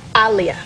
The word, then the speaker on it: Aaliyah: Say your name, Aaliyah. – Aaliyah. – Oh, jeez. – Like Muhammad Ali.